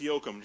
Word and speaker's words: Yoakum: It's Yoakum. Just like think Dwight Yoakum, without all the money.